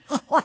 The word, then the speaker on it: Hawaii: Hawaii. You're supposed to say it like you're getting dental work done.